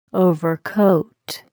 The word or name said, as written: overcoat